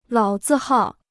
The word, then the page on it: 老字号: lǎo zì hào